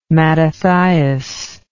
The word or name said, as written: Mattathias